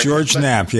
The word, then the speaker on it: George Knapp: George Knapp, yes.